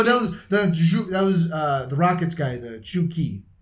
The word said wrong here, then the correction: Zhou Qi: or Qi. I don't, it's Chinese. I'm not sure exactly how to pronounce this. Z-H-O-U-Q-I. Those are two names. – Yeah. – Oh, it's Zhou Qi. – Yeah, Zhou Qi.